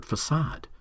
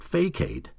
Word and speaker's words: facade: For years, I stumbled over the word facade. I pronounced it facade, like it's spelled.